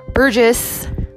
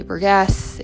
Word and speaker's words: Burgess: burgess I always get his last name wrong. I want to say Burgess, it's Burgess. but uh,